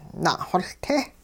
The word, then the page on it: na'xoLte': hupa